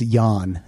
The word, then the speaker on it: Jan: It's J-A-N, but I think it's Jan. – Sure. – That's how they pronounce it.